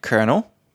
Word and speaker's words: colonel: Like colonel. Looks like colonel. – Yes. – Colonel. And I used to read a lot as well. And I was like, colonel.